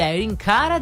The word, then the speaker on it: Irene Cara: Conquista le classifiche, il brano di Irene Cara diventa una hit mondiale grazie al film Flashdance. – L'hai detto bene, Irene Cara, brava.